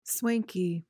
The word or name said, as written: swanky